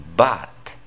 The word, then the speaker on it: but: Pero, por ejemplo, el patrón consonante U consonante, ahí la U no suena para nada como en español. De hecho, la U se pronuncia como una A. But.